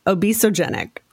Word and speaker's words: obesogenic: So, Beth, you introduced a new word to me just today, which is obesogenic. Did I say that right? And what does that mean? – Yeah. So that